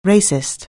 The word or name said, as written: racist